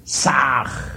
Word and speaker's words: Sah: Ham escribe con las letras H-A-M. Sah se escribe con las letras S, A, H. La H suena siempre como J.